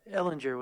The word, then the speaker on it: Ehlinger: Ellinger with the hard G. It's a hard G.